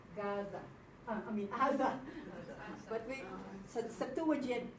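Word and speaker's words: Aza (עזה): Used to be that the ayin is pronounced, but they lost it. But in the Septuagint, when they translated into Greek, the Ga sound came out. That's why it's Gaza.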